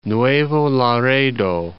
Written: Nuevo Laredo

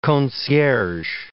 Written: concierges

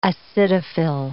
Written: acidophil

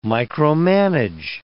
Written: micromanage

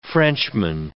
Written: Frenchman

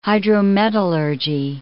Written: hydrometallurgy

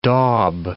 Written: daub

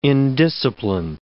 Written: indiscipline

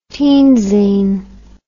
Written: teen zine

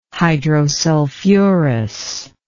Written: hydrosulfurous

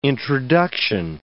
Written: introduction

